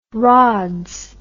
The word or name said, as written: rods